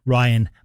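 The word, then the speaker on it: Rhionn: And if you want to find Ryan, it's spelt a strange way. It's R-H-I-O-N-N, Ryan Maxwell, brilliant artist from Glasgow.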